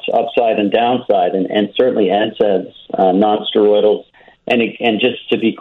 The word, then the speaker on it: NSAIDs: NSAIDs, so non-steroidal anti-inflammatory.